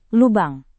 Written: lubang

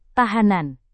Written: tahanan

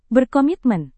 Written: berkomitmen

